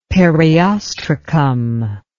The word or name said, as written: periostracum